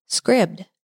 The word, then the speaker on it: Scribd: Well, how they pronounced it on the ad was Scribd.